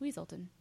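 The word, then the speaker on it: Weselton: It's actually pronounced Weaselton.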